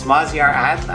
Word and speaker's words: Maziar Adl: His last name is A-D-L. So I guess that's more like Adl. Maz, can you pronounce it for us? – Yeah, it's Maziar Adl, actually.